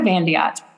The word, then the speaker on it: Van de Yacht: Yes, it's Erika Van de Yacht.